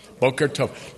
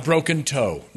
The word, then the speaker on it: Boker Tov: Boker Tov. Not broken toe. No. Boker Tov. Okay, Boker Tov is how you say good morning in Hebrew.